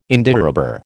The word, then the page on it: Indiarubber: American English